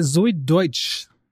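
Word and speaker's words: Zoey Deutch: Äh, Zoe Deutsch. So wird sie tatsächlich ausgesprochen, mhm. wusste ich nicht. – hätte sie Dutch wahrscheinlich ausgesprochen.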